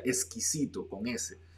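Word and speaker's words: esquisito: Sin embargo, en portugués, Existe la palabra, se escribe igual, pero se pronuncia exquisito con S.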